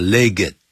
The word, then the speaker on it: legged: Before the age of mechanization, 在机械化的时代到来之前，就等于 before the coming of the age of mechanization, camels, horses, donkeys, and other four-legged creatures, 像骆驼啦、马啦、 donkeys, 驴子啦、 and other four-legged, 这个 legged, 啊，一定要那个 ED 啊发音的 legged, 好，我们就可以看得到的。